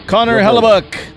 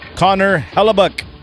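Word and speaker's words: Connor Hellebuyck: Connor Hellebuck. – What? – Connor Hellebuck. – That's a fun name. He said it right.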